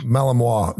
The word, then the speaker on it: Malinois: Malinois. – The Belgian Malinois.